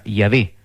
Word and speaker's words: Yahweh: Secondo gli esperti, esso si pronuncia Yahweh, nome che è stato italianizzato in Geova.